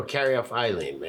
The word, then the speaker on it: caryophyllene: Is this a terpene, caryophyllene? – Oh, caryophyllene, man. Like you're carrying off Eileen, man.